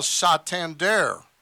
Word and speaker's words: Santander: And how did you pronounce his name? – Santana. – No, it's now Santander. – He's French. – Yeah, well, that's what he. – That's the way. – I want to make it clear he did not complain about the pronunciation of his name, but he was asked about it, and he apparently says it's really pronounced Santander.